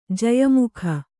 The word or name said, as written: ಜಯಮುಖ